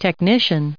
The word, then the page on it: technician: noun